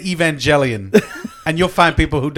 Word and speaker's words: Evangelion: Well, it's Evangelion. – It's Evangelion. Okay. – Evangelion. – So, push your glasses up. – So evangelism, evangelic, yeah. – All those are mispronounced. – Or Evangeline. – No, it's Evangelion.